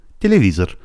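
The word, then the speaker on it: تلویزور (телевизор): تلویزور. تلویزور. حدس بزنین فشار استرس کلمه یا او درینیمون کجاست؟ روی حرف او، وگرنه او تلفظ نمیشد.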